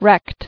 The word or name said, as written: Wrecked